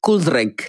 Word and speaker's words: Cool Drink: And she's done like really good collaboration with this South African artist called Cool Drink. That's how you pronounce it. Cool Drink. It's like cool drink.